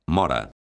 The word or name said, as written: mora